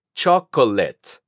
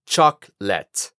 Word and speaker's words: chocolate: Aquí hay que tener cuidado, porque como nosotros decimos chocolate en español, podríamos caer en la costumbre de decir chocolate. Pero recuerda que la segunda O la eliminamos de la pronunciación. Otra vez, chocolate.